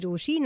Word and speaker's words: Rochie: मेरा नाम है मिर्ची रोशी, नाम की स्पेलिंग है ROCHIE, और मेरे साथ लाइन पर है मेरे मिस्ट्री कॉलर.